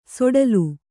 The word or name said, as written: ಸೊಡಲು